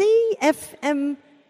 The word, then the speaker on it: CFMEU: CFFMEU. – That's not right either. It's CFMEU.